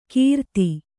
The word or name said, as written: ಕಿರಾತಿ